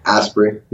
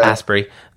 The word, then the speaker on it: Asprey: So I went to Baby Bathwater Institute, and it took me to the latest link, and that's where I saw Dave Asprey. Am I pronouncing his name right? – Asprey. Yeah. – Asprey, the Bulletproof Coffee guy, Dan Martell.